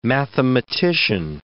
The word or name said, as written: mathematician